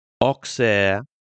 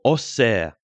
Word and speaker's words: Auxerre: Could Auxerre or Auxerre, as it's actually pronounced in France, and Angus, I know you lived there, you're an old Auxerre fan going way back.